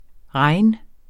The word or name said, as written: regn